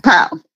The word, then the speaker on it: PAL: And then the fact that you also won this, I believe it's pronounced the PIE, or is it the PAL Award? – PAL, yeah.